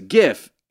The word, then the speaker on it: GIF: Of the J, but that says GIF, animated looping images, hard G.